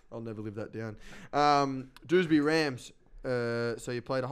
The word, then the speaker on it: Dewsbury: Is that how you say it? Dewsbury? – Dewsbury. – Bury? – Just Dewsbury, there.